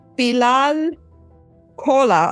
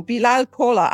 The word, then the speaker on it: Bilal Kola: Bilal Kola. Oh, I was I was actually practicing, and now I really said it wrong. Bilal Kola.